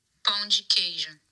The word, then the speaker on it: Pão de queijo: Pão de queijo.